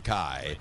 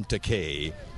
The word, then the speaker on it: Takei: I don't object to Takei, because that translates into English as expensive. But I'm Takei, which doesn't mean cheap either.